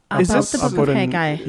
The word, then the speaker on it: Haggai: Haggai? Haggai? – Yep. – H-A-G-G-A-I? – Yeah.